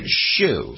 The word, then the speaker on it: show: And he of course, pronounced it shoe. – Shoe. – So they would say a really big shoe.